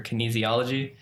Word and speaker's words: kinesiology: So I went to University of Maryland for kinesiology. – That's how you say it. He went to study kinesiology.